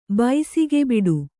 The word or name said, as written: ಬೆಸುಗೆಬಿಡಿ